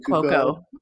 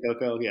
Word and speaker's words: Cuoco: Kaylee Cuoco. Mm-hmm. – Kaylee Cuoco, yeah.